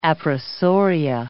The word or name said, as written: appressoria